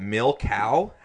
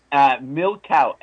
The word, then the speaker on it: milcao: It's called milcao. How do you say it? – Milcao. M-I-L-C-A-O. Milcao.